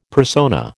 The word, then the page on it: persona: American English